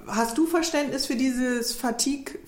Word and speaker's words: Fatigue: Du musst ein bisschen weicher aussprechen. Fatigue.